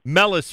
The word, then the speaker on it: Meles: M-E-L-E. Am I pronouncing your family name correctly? – Yeah. – Okay, good. – Yeah. – Mellis Family. M-E-L-E-S.